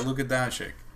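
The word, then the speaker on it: Luka Dončić: Oh, Luka Doncic? – Yeah, Luka Doncic.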